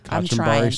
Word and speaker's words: kachambari: With coffee and barbecue, with sweet potato and corn mealy pap, and kachambari slaw. I know I'm butchering this whole thing. – I think you got that right. The kachambari slaw and the mealy pap.